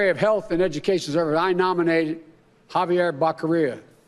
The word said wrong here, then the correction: Becerra: You know, Javier Becerra, excuse me.